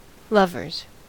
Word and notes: lovers: American English

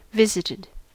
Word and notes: visited: American English

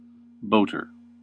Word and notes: boater: American English

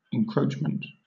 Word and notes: encroachment: British English

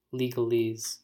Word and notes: legalese: American English

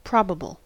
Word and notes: probable: American English

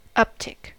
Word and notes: uptick: American English

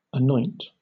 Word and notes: anoint: British English

